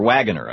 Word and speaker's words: Wagoner: Rick Wagner, who is, or Wagoner, I guess it's pronounced, is the CEO of GM.